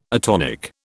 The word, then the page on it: atonic: British English